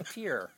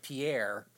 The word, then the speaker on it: Pierre: You had to walk to pier. Or, as everybody else in the United States would call it, Pierre.